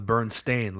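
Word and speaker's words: Berenstain: But it's actually spelled with an A. – Yeah, the Bernstein, like a stain.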